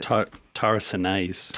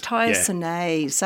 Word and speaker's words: tyrosinase: And also with aging cells, that tyrosol, it also... – Tyrosinase. – Tyrosinase, yeah. That's how you say it.